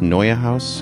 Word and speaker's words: Neue House: N-E-U-E House? Neue House? Everybody, is that it? Yes. Neue House.